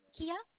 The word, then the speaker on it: Keya: Kia. – Can you spell that for me? – K-E-Y-A.